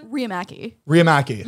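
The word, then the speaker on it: Riihimaki: How do you pronounce it? – Riamaki. You were super close.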